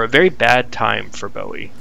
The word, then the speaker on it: Bowie: Yeah, you're aware of this David Bowie character? Bowie, I believe it's pronounced.